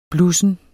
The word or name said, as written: blussen